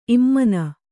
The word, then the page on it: ಇಮಾನಿ: kannada